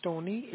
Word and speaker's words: Tony: My name is Tony. It's spelled like T-O-N-Y with the last name initial as a Sam.